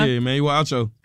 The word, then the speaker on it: Emmanuel Acho: Is it Emmanuel Acho? – How do you say? Uh-huh. Yeah, Emmanuel Acho.